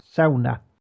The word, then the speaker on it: sauna: And suggested myself, basically she invited everyone, but two of the people we were with decided to go somewhere else to give it the correct Nordic pronunciation, sauna.